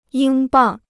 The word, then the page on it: 英镑: yīng bàng